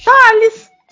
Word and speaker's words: Tails: Não, Tails eu sempre pronunciei Tails. – Pô, mas quem era pra você? Era Thaís? Thales!